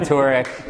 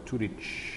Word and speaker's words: Turek: What? How do you say it? – Turek. – Turek. – Like Zurich. – What is a liquid U? – A liquid is when you make the U sound like Turek. – Turek. That's kind of what you were doing. – So you say Turek. – Yeah. – Turek, like Zurich. – Yeah, and I shouldn't because it's a Croatian name and it's supposed to be pronounced Turic. – Really?